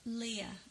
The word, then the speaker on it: Leah: Leah. – Leah Lesesne. – Leah Lesesne. – Oh, Leah. – Like Star Wars. Leah.